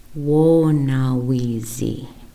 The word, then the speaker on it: wonawizi: Our next term here is wonawizi. Wonawizi. Translation for wonawizi is jealousy. Let's pronounce that together. Wo na wizi.